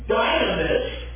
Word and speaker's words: dynamis: There, upsilon, there, U, is sometimes pronounced like the long I. So, dynamis, if we were to go to pronounce it now as dynamis, sounds like what, obviously? Our dynamite.